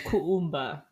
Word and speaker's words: Kuumba: I think it's Kuumba, because I think you pronounce every letter in Swahili.